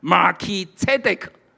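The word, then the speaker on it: Melchizedek (מלכי־צדק): 希伯来文是这样发音的：Melchizedek， Melchi 就是说我的王，Zedek 是仁义。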